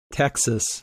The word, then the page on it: Texas: English